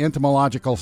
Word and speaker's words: Entomological: The Entomological Society of America. Wow, I even said that. – You said it right.